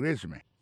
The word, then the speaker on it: résumé: Alors là encore si on a parlé de accent tombé sur premier syllabe. It's called a résumé.